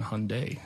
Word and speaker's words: Hyundai: It's always refreshing to be reminded that I was saying it right my entire life, saying Hyundai, not Hyundai, that I was the one that was always correct. And in the Hyundai, like Sunday, it's Hyundai.